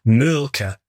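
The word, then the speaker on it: Mölkky: We use Malky. Most of the people we play against use Malky, but the Finns have a very unusual pronunciation, which, please forgive me, I'll try, it's Mulka.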